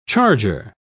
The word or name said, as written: charger